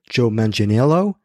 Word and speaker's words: Manganiello: played by Joe Manganiello. I believe that's how you pronounce it, Joe Manganiello from Magic Mike.